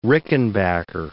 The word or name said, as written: Rickenbacker